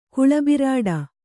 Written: ಗಾಳಿಬುರುಡೆ

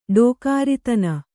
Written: ಡೋಕಾರಿತನ

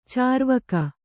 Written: ಚಾರ್ವಾಕ

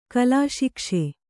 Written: ಕಲಾಶಿಕ್ಷೆ

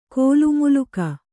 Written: ಕೋಲುಮುಲುಕ

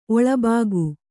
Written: ಒಳಭಾಗ

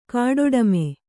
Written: ಗೊಡ್ಡೆಮ್ಮೆ

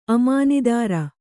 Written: ಅಮಾನಿದಾರ

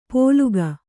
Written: ಪೋಲುಗ

